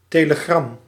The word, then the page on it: télégramme: [te.le.ɡʁam]; France